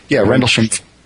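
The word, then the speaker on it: Rendlesham: I don't even know how to pronounce that, Rendlesheim. – Rendlesheim. – Rendlesheim.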